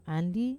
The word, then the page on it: andi: Wamesa